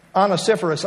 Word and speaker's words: Onesiphorus: Now, there's a name I always have to think about how to pronounce, since half the time, at least, I pronounce it wrong. But Onesiphorus, I think, is the correct pronunciation.